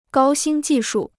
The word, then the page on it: 高新技术: gāo xīn jì shù